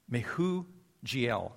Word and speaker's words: Mehujael: Now to Enoch was born Irad, and Irad became the father of Mehujael. That's how it's pronounced, Mehujael.